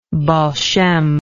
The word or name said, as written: baal shem